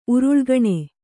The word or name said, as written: ಉರುಳ್ಗಣೆ